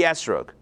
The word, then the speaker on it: esrog: I'm telling you what it means, and make sure to tell everyone else it means the esrog, or the etrog. I'm just going to pronounce it like the Ashkenazi way.